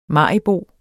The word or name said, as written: maribo